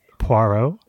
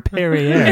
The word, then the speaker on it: Poirot: Justin, I just want to say that a fancy dinner opening as the staging point for what I hope is a Pirouar? Wait, how do you say his name? – Poirot. – Yeah. – Inspector Poirot. – Inspector Perrier. – Inspector Perrier. – Poirot.